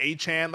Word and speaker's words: Etienne: Like, this is not like a Travis Etienne, which is actually Achan.